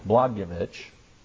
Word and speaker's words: Blagojevich: I think that's how you pronounce it. I believe the J in Polish is a Y sound. Blagojevich.